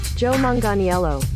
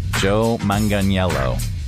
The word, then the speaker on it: Joe Manganiello: Joe Manganiello. – Joe Manganiello.